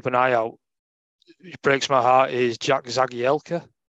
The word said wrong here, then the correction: Jagielka: Zach Jagielka, say that correctly, put my teeth back in.